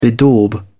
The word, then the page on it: bedaub: British English